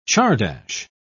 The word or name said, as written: czardas